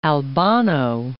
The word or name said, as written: Albano, Lake